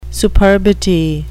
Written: superbity

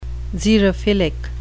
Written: xerophilic

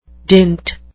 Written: dint

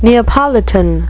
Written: Neapolitan

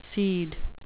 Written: cede

